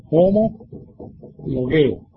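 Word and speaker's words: homologeo: Homologueo. Esta palabra homologueo, así se pronuncia.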